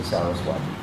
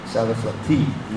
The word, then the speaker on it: Sarasvati: Most people mispronounce it, they say Saraswati. Sarasvati, you know, you got to pronounce Sanskrit properly.